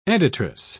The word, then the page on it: editress: British English